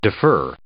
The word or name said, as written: defer